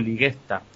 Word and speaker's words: Ligesta: Escrito es Ligesta con G, pero se pronuncia con G-U, Ligesta.